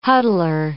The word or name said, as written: huddler